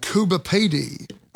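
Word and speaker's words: Coober Pedy: And I did take the Indian Pacific to places like Kalgoorlie, a mining town, and Kuba Pedi.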